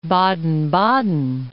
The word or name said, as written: Baden-Baden